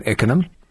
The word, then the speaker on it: Ickenham: David, I used to live in Ickenham. – Did you? – Yeah, which is well named. The first syllable is Ick.